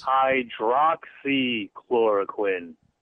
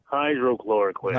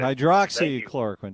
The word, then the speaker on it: Hydroxychloroquine: Hydroxychloroquine, not hydrochloroquine. – Hydroxychloroquine.